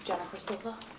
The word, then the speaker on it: Jennifer Silva: I'm Jennifer Silva. – Just spell it. – S-I-L-V-A. – Thank you. – Joseph Silva, S-I-L-V-A.